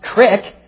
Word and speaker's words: creek: But the meaning is neither here nor there, just like the pronunciation of, or the meaning of the word creek doesn't matter when you hear a Western Pennsylvanian pronounce it crick.